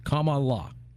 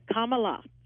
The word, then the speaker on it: Kamala: Just think of comma, like a comma, an explanation comma. It's Kamala. – Gotcha. Kamala.